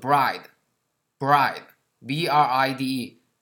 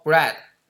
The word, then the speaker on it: bride: 叫做 bride，bride，b r i d e，很多同学把这个新娘 bride 啊读成了面包 bread 啊，两个是不一样的，面包是 bread，新娘是 bride 啊，b r i d e，bride。